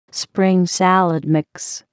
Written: spring salad mix

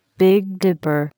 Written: Big Dipper

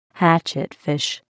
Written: hatchetfish

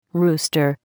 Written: rooster

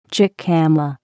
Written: jicama

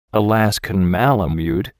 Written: Alaskan malamute

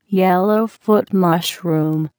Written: yellow foot mushroom

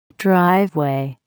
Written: driveway

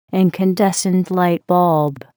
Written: incandescent light bulb

